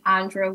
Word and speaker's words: Aundra: So all of my social media handles are under my name, Andra Williams, or Andra.Williams.